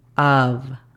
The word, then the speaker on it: of: Of, la pronunciación fonética sería A-F, pero la F es muy suave. Casi, casi entre la F y la V. Of. A-F. Of. The sería D-A. The. Por último, devil, la pronunciación fonética sería D-E-V-O-L.